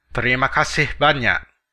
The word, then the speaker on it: terima kasih banyak: In Indonesian, the formal way of expressing extreme gratitude, like thank you very much in English, is terima kasih banyak.